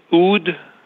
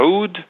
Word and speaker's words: oud: Am I saying it right? The oud and the bouzouk? – The oud or oud and the bouzouk.